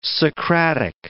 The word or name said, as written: Socratic